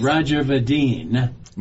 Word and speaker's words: Roger Vadeen: And Roger Vadeen.